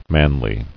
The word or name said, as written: Manliness